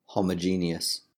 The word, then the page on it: homogeneous: British English